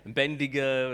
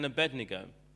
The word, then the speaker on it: Abednego: That name gets so mispronounced. Bendigo and all kinds of things. But Shadrach, Meshach, and Abednego, and their story, they did not bow down to the idol.